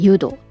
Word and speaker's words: 湯道: 湯に道と書いて誘導ですえ公開が、えー、 2 月 23 日からで公開日から対応しています。